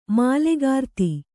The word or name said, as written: ಮೂಲಕೃತಿ